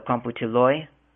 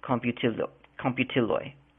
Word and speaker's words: komputiloj: So computiloi, that's actually spelled K-O-M-P-U-T-I-L-O-J, computiloi. Ekranoi, that's a J at the end. So it looks like computiloge, but it's computiloi. J is pronounced as a Y in Esperanto.